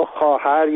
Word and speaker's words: خواهر: به عنوان مثال، ما خواهر یا خواستن رو با واو می نویسیم، در حالی که این صدا آ هست. علتش اینه که در گذشته خواهر یا خواستن تلفظ می شده.